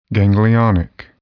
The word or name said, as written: ganglionic